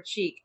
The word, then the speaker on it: Cheek: You can find me at Jennifer Cheek on Twitter. And my last name is spelled C-H-E-E-K. Just like that thing on your face. Cheek.